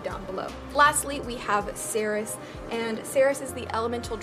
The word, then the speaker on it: Sairys: and it is spelled S-A-I-R-Y-S and is pronounced S-A-Y-R-I-S-S. So like Sarah-is, you know what I'm saying? Not Sarah Weiss, because that's kind of how it's like spelled.